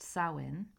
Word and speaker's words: Samhain: The ancient Celts celebrated Samhain, pronounced Samhain, on the night in late October.